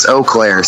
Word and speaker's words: Eau Claire: It's Eau Claire.